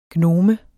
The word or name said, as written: gnome